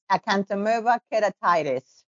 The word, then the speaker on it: acanthamoeba keratitis: Today, we introduce a patient advocate who will speak about acanthamoeba keratitis. – No. – How do you pronounce that? Welcome to the podcast. – Thank you. It's acanthamoeba keratitis.